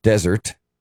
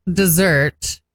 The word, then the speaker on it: Desert: Mount Desert is French and you pronounce it that way.